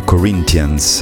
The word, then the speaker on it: Corinthians: Io sono abituato a pronunciare la T in questo caso, come si dice, come si fa in greco e in latino, ma gli inglesi dicono Corinthians ed è riferito agli abitanti di Corinto.